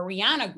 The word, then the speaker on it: Rihanna: Yes, Rihanna, because that's how you say it properly, yes. Right? Rihanna.